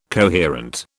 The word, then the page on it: coherent: British English